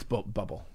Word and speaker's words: Bublé: Michael Bubbly. – It's Bubble. But it's Michael Bubble. – Stroke. You got to say it right.